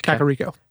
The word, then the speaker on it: Kakariko: Is it Kakariko Village? – Kakariko. – Kakariko Village.